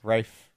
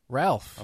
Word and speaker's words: Ralph: Rafe. – Yeah, what Rafe. Did I say? – Ralph.